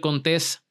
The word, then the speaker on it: Contés: Mi nombre es Miguel Contés con acento en la E y nos vemos la próxima.